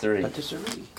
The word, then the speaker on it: Patisserie: Patisserie.